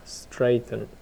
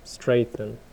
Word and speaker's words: strengthen: Strengthen. – Straighten.